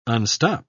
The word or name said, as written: unstop